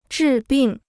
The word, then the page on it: 治病: zhì bìng